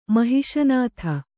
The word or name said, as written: ಮಹಿಷನಾಥ